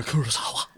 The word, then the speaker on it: Kurosawa: Akira Kurosawa.